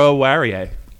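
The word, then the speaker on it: Oruwariye: I'm the only guy that can pronounce this guy's name. – I don't want to butcher it. Aro-Warie. It's not really that hard.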